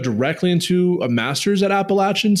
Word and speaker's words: Appalachian: You got that at good old Appalachian State University down there in Boone, North Carolina.